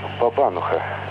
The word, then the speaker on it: бабануха: И вот Ну, во-первых, вы неверно ставите ударение. Вот в одном слове. Вы так интересно рассказывали о хреновой бабанухе. Но надо ставить бабануха.